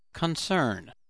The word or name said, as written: concern